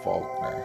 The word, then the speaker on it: Faulconer: At least that's how I pronounce his last name. It's spelled F-A-U-L-C-O-N-E-R. First name, Kevin Faulkner.